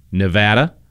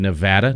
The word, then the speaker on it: Nevada: West Virginia, Pennsylvania, South Carolina, Nevada. Yes, I said Nevada, not Nevada. So all of our friends in Nevada, just want you to make note that I said it right.